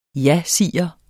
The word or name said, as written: jasiger